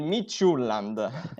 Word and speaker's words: Midtjylland: È una pronuncia difficilissima, ma abbiamo quella ufficiale. – Vabbè, dillo. – Il Midtjylland. – L'ha detto. Una squadra impronunciabile, io ho sempre detto Midtjylland, però vabbè, ci fidiamo della pronuncia di Sky.